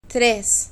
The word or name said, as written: tres